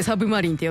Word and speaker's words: 魚影: ね、あのこちらあの名探偵コナンの楽曲ということでね、あの、うん、魚影と書いてサブマリンって読むあの例の映画のみたいな主題歌でございました、はい、でこちらあの森蘭の声担当されていらっしゃった声優のね山崎若菜さんが亡くなられたということで、こちらのリクエストいただいておりました。